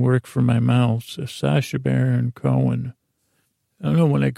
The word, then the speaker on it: Sacha Baron Cohen: I guess if you say it like Sacha Baron Cohen, that's how you say it, Sacha Baron Cohen. You gotta say it fast.